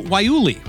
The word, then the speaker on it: Guayule: Now, we won't be testing you on your ability to describe or pronounce Cis-1-4 polyisoprene or even to spell Guayule, which, by the way, starts with a silent G and looks like it would be pronounced Guayule.